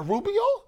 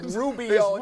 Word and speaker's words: Rubio: She's like, is that a Rubio? I said, stop rolling your tongue when you say his name around me like that. – Yeah. – It's Rubio, not Rubio.